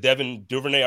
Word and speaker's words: Duvernay: You know, I know this year, what is it, Devin Duvernay, I always say it wrong.